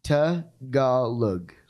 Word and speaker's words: Tagalog: Tagalog.